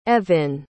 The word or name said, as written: Evin